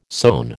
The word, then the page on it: sown: American English